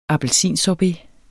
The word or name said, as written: appelsinsorbet